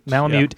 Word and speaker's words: Malamute: Am I pronouncing that right, correct? Correctly, Alaskan Malamute? – Malamute. Yeah. Malamute.